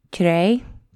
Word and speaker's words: Tire: But this last week, we actually took a group of ladies to a town outside of Izmir called Tiray. I always say it wrong. It looks like tire. It's actually spelled like tire, which is probably why I completely butcher it every time that I say it.